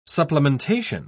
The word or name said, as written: supplementation